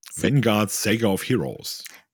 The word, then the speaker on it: Vanguard Saga of Heroes: wie spricht man das überhaupt nochmal aus? – Vanguard Saga of Heroes.